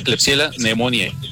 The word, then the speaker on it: Klebsiella pneumoniae: Se pronuncia Klebsiella pneumoniae. Son nombres en latín y así se pronuncia.